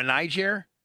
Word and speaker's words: Niger: Maybe like just north of Nigeria. – Yeah, you want to go to Rwanda. – I want to go to Niger? – Yes. – Is that how you say it? – Yeah.